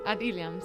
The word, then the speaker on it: Ad Ilham: Para ella, el título del disco, Ad Ilham, ¿lo he dicho bien? – Ad Ilham, sí.